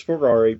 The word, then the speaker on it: Ferrari: Ford versus Ferrari. Oh my gosh, say it like a normal person. – I'm trying. Ford versus Ferrari, Parasite, and Doctor Sleep are all movies.